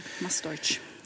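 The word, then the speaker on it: Mas Doix: Producenten här heter, hur uttalar du det? Mas Deutsch. – Mas Deutsch. Det måste vara katalanska också, väl, att det är D-O-I-X, va? – Ja, D-O-I-X, precis. – Mastoich.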